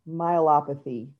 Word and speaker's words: myelopathy: So with the, how do you say it again? The myopathy? Oh, just call it DM. It's degenerative myelopathy.